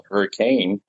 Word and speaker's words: Hurricane: It was called Hurricane. Well, if you're local, you'd call it Hurricane, but it's spelled Hurricane.